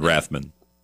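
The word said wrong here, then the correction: Ramthun: Is it Rathman? Did I say that right? – It's like, Ramthan. Sorry.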